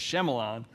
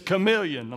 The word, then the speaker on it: chameleon: So if you're ever reading the word and you feel a little intimidated, just remember, I read chameleon as Shyamalan. To which my Bible study group, wait a minute. I said, what? One of y'all think you know how to pronounce that word better? Yeah, actually we do. It's chameleon.